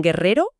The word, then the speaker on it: guerrero: Para que suene fuerte con la E y la I, necesita una U en medio. – Una U que no se pronuncia. – Exacto. Como en guerrero o guitarra.